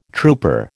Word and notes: trooper: American English

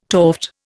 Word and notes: toft: Indian

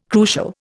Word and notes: crucial: Indian

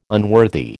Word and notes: unworthy: American English

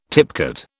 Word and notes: tipcat: British English

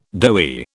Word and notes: doughy: British English

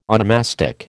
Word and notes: onomastic: American English

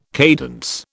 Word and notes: cadence: British English

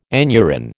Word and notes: aneurin: American English

